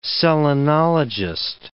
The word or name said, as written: selenologist